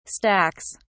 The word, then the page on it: stacks: British English